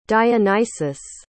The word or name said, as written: dionysius